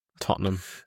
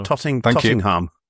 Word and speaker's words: Tottenham: There's no second T in the way Chris pronounced Tottenham. Correct? – Dear me. – Tottenham. – In the cup final. Yeah. Tottingham.